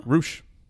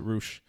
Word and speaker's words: Rush: Roosh. It's spelled Rush. It's R-U-S-H, but you pronounce it Roosh.